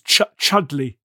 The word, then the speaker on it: Chudleigh: Elizabeth, how do we pronounce it? Is it one of those English names? Chudleigh. – How should we put it? Yes. – Okay, it's not like spelt Chudleigh, but actually pronounced Chum or something.